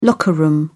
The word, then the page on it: locker-room: British English